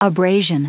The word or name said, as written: abrasion